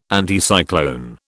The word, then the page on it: Anticyclone: British English